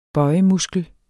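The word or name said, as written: BØJEMUSKEL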